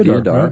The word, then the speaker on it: deodar: Other fragrances that have a similar effect are frankincense, fresh mint, deodar. Is that the way you pronounce it? Yeah, deodar.